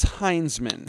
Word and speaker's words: Heinzman: It's a Is it Hinsman or Heinzman? – I believe it is Heinzman. – That's what I thought too. And then I watched a video today where someone was pronouncing it Hinsman and it's almost spelled Hinsman. I always thought it was Heinzman. – I'm going to say Heinzman. – We're going with Heinzman.